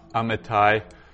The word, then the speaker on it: Amittai: Amittai. So opening with an aleph, which is just a placeholder. It really doesn't have any sound. Then we've got a very short A.